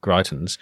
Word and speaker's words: Greitens: Disgraced former Missouri governor and GOP Senate candidate Eric Greitens, is that his correct pronunciation? – Yes.